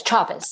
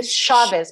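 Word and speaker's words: Chávez: Without pronouncing it incorrectly, is it Chavez? – Yes, Chavez. – Okay. – A lot of people say Chavez, but it's Chavez.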